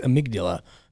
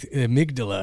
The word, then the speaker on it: amygdala: Until like literally like a year ago, I heard someone say like amygdala, and I was like, oh, I've been saying that wrong.